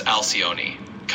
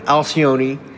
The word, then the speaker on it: Alcyone: How did you pronounce that? – I usually say it as Alcyone, kind of rolling it out like that. But hey, pronunciation can be a little flexible, so whatever feels comfortable works.